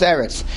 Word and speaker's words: Eretz: Eretz, sometimes it's Aretz, sometimes Eretz. We talked about this yesterday. At the end of a phrase, at the end of a pasuk, it's Aretz.